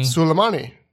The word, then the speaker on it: Soleimani: Soleimani.